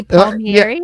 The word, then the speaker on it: Palmieri: So I, oh my, am I saying it right? Eddie Palmieri. Uh, yeah.